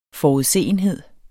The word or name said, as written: forudseenhed